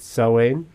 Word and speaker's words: Samhain: Am I saying it right, Samhain? – Right.